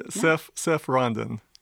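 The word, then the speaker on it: Seph Ronden: Seph Rondon. – Okay, wait. Spell that. – S-E-P-H. – S-E-P-H. – R-O-N-D-E-N. – R-O-N-D-E-N.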